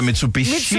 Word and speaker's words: Mitsubishi: Nej, den hedder Mitsubishi.